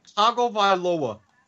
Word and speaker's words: Tagovailoa: And by the way, Tagovailoa. – Wait, how's it pronounced? – Tagovailoa.